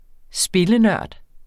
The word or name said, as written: spillenørd